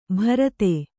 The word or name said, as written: ಬರ್ದೆ